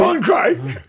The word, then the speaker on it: Cronkite: And at that time, the newsreader that everyone knew was Walter Concrete. – Walter Concrete. – Cronkite. Cronkite. I can't even say it right because I used to make fun of it. Cronkite. This is Walter Cronkite.